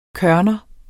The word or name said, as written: kørner